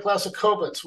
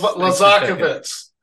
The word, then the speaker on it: Laszakovits: And this morning I have Greg Laszakovits with GDL Insight. Greg, did I get the name right? – You're very close. I'll give you an A for effort. Greg Laszakovits. Thanks. – Laszakovits. My apologies.